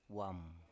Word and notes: wam: Biak

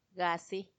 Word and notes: gaasi: Macuiltianguis

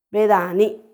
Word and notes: bedaani: Macuiltianguis

